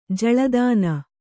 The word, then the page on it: ಜಳ್ಳುತೆನೆ: jaḷḷu tene